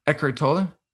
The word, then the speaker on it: Eckhart Tolle: Eckhart Tolle.